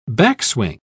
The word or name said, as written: backswing